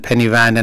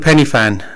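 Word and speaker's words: Pen y Fan: I think there was a whole round of Penny Fan and Penny Van and all sorts of various different pronunciation of it. – And so what is the pronunciation? – I've been told it's called Penny Fan.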